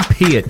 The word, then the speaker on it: Peart: Lee Peart, to rhyme with ear.